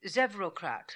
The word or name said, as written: Zevrocrat